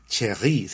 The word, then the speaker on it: Cherrier: Yo conocí a una cantante francesa que se llama Marie Cherry, se escribe Marie Cherrier y,